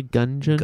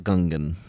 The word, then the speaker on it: Gungeon: you pronounced that correctly? Gungeon? – Gungeon. – Okay, just making sure. – Yes, not Gungan. It's not another Star Wars thing.